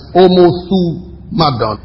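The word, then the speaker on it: Omothumadon (ὁμοθυμαδόν): It is, I'll spell it for you because it's like Omothumadon. H-O-M-O-T-H-U-M-A-D-O-N. H-O-M-O-T-H-U-M-A-O-N-M-A-D-O-N.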